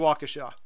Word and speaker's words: Waukesha: How do you pronounce that? – Waukesha, I believe. I think my in-laws are from there. – Waukesha. – Yeah. – Where is that? Wisconsin, right? – Yeah, that's it. It's definitely Waukesha.